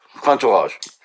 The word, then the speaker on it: Cantourage: Da ist uns aufgefallen, Cantourage, wie spricht man das denn aus? – Cantourage. – Französisch.